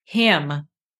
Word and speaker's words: hymn: Hymn. – Yeah. So same pronunciation as like him and her.